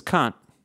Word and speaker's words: Kant: It's spelled K-A-N-T, and it's German from like 18th century, and it's pronounced Kant. It's only been very recently, Americanized as Kant? Kant, I think is what they're going. But no, that's K-A-H-N-T. His name is K-A-N-T, and the German pronounced Kant.